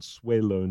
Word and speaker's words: Swaylo: His name is spelled S-W-A-Y-L-O, Swaylo.